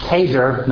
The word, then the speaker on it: Cheder: Now it is the tradition in Judaism to begin a child's what's known as Keder, that's C-H with the hard C-H, E-D-E-R, Keder, kind of elementary school, Beit Sefer, kindergarten, if you will, to begin their Cheder education with the first chapter of Leviticus.